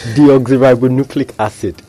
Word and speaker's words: deoxyribonucleic acid: Deoxyribonucleic acid. – Let me see if I can try. Deoxyribonucleic acid.